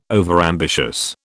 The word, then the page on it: overambitious: British English